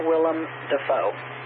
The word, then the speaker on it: Willem Dafoe: Willem Dafoe. – You, sir, are correct. Willem Dafoe. And you said it right, too.